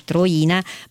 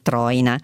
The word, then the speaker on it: Troina: e grazie all'ascoltatore che mi dice non si dice Troina ma Troina, ok,